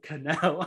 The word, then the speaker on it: Connell: And I was like, I pronounce it Connell. I don't know.